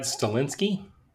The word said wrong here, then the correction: Stahelski: Tad Chad Stahelski, the guy from uh the guy from John Wick. – Yeah. – Yep. – That, that's him. Yeah. – What's how do you say his last name? I think it's Chad Stahelski. Stahelski. – All right. I think so.